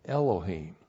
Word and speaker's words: Elohim: That's Aleph. E-L-O-H. Long I-M. Elohim. And see that eem ending right there? That's the plural ending.